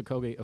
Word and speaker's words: Okogie: Well, it's Okogie, officially.